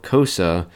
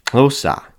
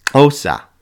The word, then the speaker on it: Xhosa: And it's not called Xhosa, but that XH sound actually makes a clicking of the tongue from the side of your tongue, and it's pronounced Xhosa. That's right, it's not Xhosa, it's Xhosa.